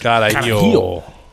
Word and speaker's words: carajillo: I had a Carajillo in Vegas. It's Carajillo.